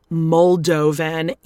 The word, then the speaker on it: Moldovan: First of all, it's Moldovan. Even I could learn that, and I was saying it wrong for a good 2 months before I was corrected, but I made sure to say it correctly from here on out.